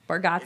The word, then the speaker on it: Bargatze: last name correct with Bargatze. – Bargatze. – And I think we say it wrong as a family. Bargatze. For years, I've been calling you Nate Bargatze.